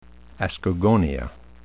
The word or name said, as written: ascogonia